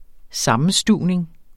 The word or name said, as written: sammenstuvning